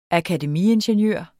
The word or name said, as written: akademiingeniør